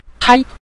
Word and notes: tai: tai5

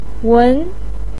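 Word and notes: wen: tone 2

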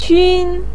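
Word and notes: qun1: tone 1

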